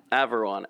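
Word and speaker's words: Averoigne: And how did you pronounce it? And that's how we're going to pronounce it? – Averwan. – Averwan. Okay, I think that's how Ken pronounced it the other day, too.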